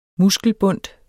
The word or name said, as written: muskelbundt